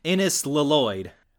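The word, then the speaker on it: Innes Lloyd: Sorry, Ines Lloyd. I keep forgetting that's how his name is pronounced. – Ines. – Ines Lloyd.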